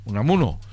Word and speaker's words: Unamuno: De Unamuno se dice, ¿verdad? – Unamuno.